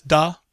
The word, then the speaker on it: Da: And that is actually how it's pronounced. It's Da, D-A, Sweet Blood of Jesus.